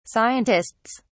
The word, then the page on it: scientists: British English